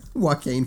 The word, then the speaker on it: Joaquin: It's got Charlize Theron and big Joaquin Phoenix. I can never say his name. Joaquin. – Joaquin Phoenix. – Joaquin, there you go. Joaquin.